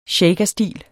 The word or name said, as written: shakerstil